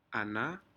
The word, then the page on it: anah: Aneityum